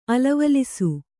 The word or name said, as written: ಅಲವಲಿಸು